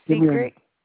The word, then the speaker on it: Secret: My name is Secret. – How do you spell it? – S-E-C-R-E-T. – Esther? – S-E-C-R-E-T.